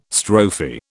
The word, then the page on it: strophe: British English